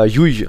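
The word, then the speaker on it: Llull: Ach, Jui. Jui. Jui. Wie spricht man aus? Ja, Jui eigentlich. Jui. – Ne? – Zweimal J. Doppel, Doppel L, U, Doppel L.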